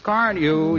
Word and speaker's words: Carnu: More car owners buy Johnson's Car New, you know, than any other car polish. It's spelled C-A-R-N-U.